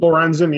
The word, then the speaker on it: Lorenzen: Is that how you say his name? – Lorenzen, yeah.